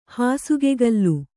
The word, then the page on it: ಹಾಸುಗೆಗಲ್ಲು: Kannada